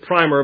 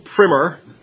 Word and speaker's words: primer: Some people want to pronounce it primer, but that makes me think of a coat of paint. So primer.